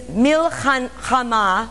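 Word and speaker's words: milchama (מלחמה): in Hebrew, is milchama, milchama, which is spelled M-I-L, for those of you that are interested, H-A-M-A-H. Of course, in the Hebrew lettering, it wouldn't be spelt like that. That's a transliteration. M-I-L-H-A-M-A-H.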